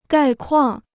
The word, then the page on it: 概况: gài kuàng